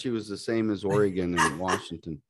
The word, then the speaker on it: Oregon: See, it's Oregon. I made sure I said that right.